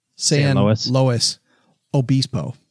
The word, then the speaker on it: San Luis Obispo: Well, no, that's the way it is pronounced. San Luis. Luis Obispo. – It's Obispo. Like, it's Obispo. It's actually way better than mine.